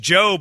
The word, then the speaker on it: Jeaux: It's just Joe, but it's spelled J-E-A-U-X.